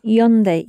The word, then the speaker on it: yonde: Kare wa yaunde imashita. – Very close with your pronunciation. Yonde imashita.